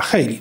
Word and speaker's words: Haley: czy Haley, ja nie wiem jak się wymawia jej nazwisko, Haley, Y, tak ma... jak z tej y, piosenki niemieckiej trochę, takiej, co ma maszerowali żołnierze. – A, Hayley. No dobrze.